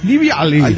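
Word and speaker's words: Živjeli: Sí, en realidad se escribe la Z con una casita arriba, una I, una V. Bueno, se pronuncia Liviali. – Liviali. – Se pronuncia nada que ver como se escribe, ¿eh? – Póngale.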